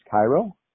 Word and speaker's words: Kairo: Her name is Cairo, not like Cairo, Egypt, but pronounced that way, but spelled K-A-I-R-O.